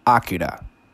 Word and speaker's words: Akira: Akira, or to properly pronounce it, Akira.